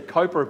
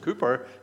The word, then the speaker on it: Cowper: William Cooper, or we sometimes would say Cowper, but actually it's supposed to be pronounced Cooper, I'm told.